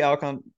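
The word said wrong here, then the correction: Alcántara: Alcon, I can never say his name. – Alcantara, There we go.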